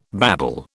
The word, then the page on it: babble: British English